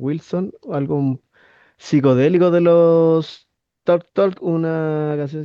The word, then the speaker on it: Talk Talk: Carlos, desde Curarrehue, nos pide la banda Talk Talk, que podría pronunciarse mejor como Tok Tok, algo así más en inglés. Pero se escribe Talk Talk.